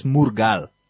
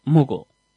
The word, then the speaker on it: Mughal: If you read it, it says Murgal. – Mughal. – Yeah, but you have to pronounce like you're pronouncing it.